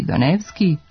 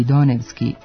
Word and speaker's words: Donevski: Zato ćemo makedonska prezimena sa završetkom ski izgovarati u skladu sa svojim navikama, a naše jezičke navike išle su ka tome da prenošenje akcenta ne bude izvorno, nego pomereno za jedan slog unazad, pa će se prečuti Ilijevski i Donevski, a ne Ilijevski i Donevski, kao u makedonskom.